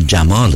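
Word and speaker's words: Yamal: فامیلش رو به جای یامال جمال تلفظ میکنن، جمال.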